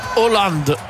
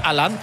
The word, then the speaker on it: Haaland: Paris qu'on doit dire Hollande, si on suit vraiment purement la prononciation norvégienne, mais tout le monde l'appelait Haaland.